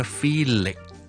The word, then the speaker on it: Felix: Felix F-E-L-I-X 这个英文名同埋 Phyllis P-H-Y-L-L-I-S 这两个发音有什么分别呢？听落其实都几大分别，听一次，第一个是 Felix Number two Phyllis OK。最紧要的分别就是 Felix F-E-L-I-X 因为个 X 咧，其实系代表 K S 两个音嘅，OK，所以我听到 Felix